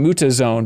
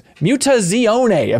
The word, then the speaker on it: Mutazione: I've been calling it Mutazone, but it is Mutazione, apparently is how you pronounce this, and it's the vinyl soundtrack to that.